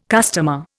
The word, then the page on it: customer: Indian